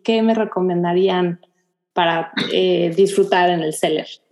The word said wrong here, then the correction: Celler: Tenemos que decir que se dice el Celler.